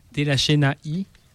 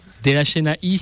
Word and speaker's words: De La Chena: Alors je sais pas pour la prononciation, je pense que je le prononce probablement mal, mais c'est Delachenaï. Peut-être tu peux me corriger là-dessus. – Shadi. – Ah. Delachenaï, c'est bien comme ça qu'elle se prononce? – Non. – Le nom d'un morceau, De La Chena.